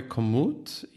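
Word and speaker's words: Komoot: A, to, znaczy, to się chyba wymawia commute i to ma po prostu podwójne O w środku.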